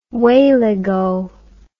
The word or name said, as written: wayleggo